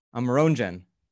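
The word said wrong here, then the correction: Amerongen: Very close. Amarongen. – Amarongen, of course, the proper Dutch pronunciation.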